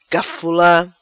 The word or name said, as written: Cafula